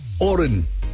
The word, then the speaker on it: Horan: Yes, he prefers the silent H. Oren. H-O-R-A-N.